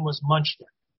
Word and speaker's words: Muchnick: You said Muchnick. – I'm sorry. – The general. No. That's why we're here. We learned this. The general pronunciation was Muchnick. – Muchnick. Okay. – Just more the way it looks.